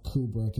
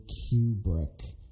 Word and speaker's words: Kubrick: And to any of you who don't know, according to his daughter, his name is not Kubrick, as a lot of people say. It's actually Kubrick. – Like in Q-tip. – Yeah, pretty much. That's pretty much how you pronounce his name.